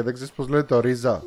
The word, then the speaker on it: RZA: RZA εννοεί το Ρίζα. Α, οκ. – Ρίζα προφέρεται.